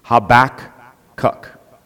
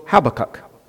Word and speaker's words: Habakkuk: Habakkuk. If you're influenced by American English, the first K is silent, and so it's Habakkuk. If you're influenced, however, by Hebrew, it will be Habakkuk.